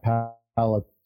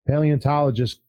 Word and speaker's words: paleontologist: How do I pronounce that, palate? – Paleontologist